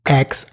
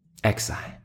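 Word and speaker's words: X Æ A-Xii: Diesem Kind gesagt, also sie würde es Exi aussprechen. Exi.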